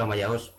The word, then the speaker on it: Amaya OS: ¿Cómo lo pronuncias? ¿Cómo se dice? – Me da igual, pero yo lo pronuncio Amaya OS, pero Amaya OS está bien.